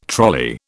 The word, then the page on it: trolley: British English